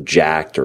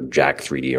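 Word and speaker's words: Jack3d: with Jacked or Jack 3D, or obviously it's Jack is how they want it to pronounce. But a lot of people say Jack 3D, so you know what I'm talking about.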